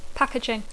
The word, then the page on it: packaging: English